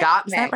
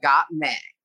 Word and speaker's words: Gottmik: Am I saying this right? Gottmik? Gottmik? – Gottmik. Right? Yes. Like got milk, but Gottmik.